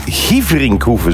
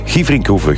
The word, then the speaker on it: Gieverinkhoeve: Benoît Dorné stuurt, hey Björn, de rally is in Gieverinkhoeve, zo spreek je het uit. Gieverinkhoeve, groetjes, de papa van Jules.